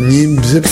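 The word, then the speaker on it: Niépce: Pas Diep, Niep. – Ça s'écrit N-I, accent grave, P-C-E, voilà. C'est Niep, Niep, voilà.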